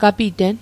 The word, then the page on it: gapiten: Mi'gmaq